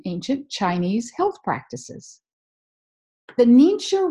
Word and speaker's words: Ningxia: So I want to welcome you today, and I'm going to talk today about a product called Nincha Red. That's spelled N-I-N-G-X-I-A, Red. Have you heard of it? Or have you heard of the Nincha Wolfberry?